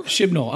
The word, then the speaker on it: Chibnall: Chibnall.